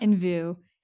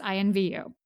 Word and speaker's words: I Envy You: It's spelled just like that with like no periods. So you may try to pronounce it wrong. No. It's not like it's not in view. It's I envy you.